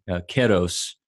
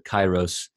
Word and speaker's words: kairos: And then keros, or some people might pronounce it kairos.